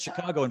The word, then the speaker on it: Chicago: And I said Chicago in front of some people the other day. They said, you said it right.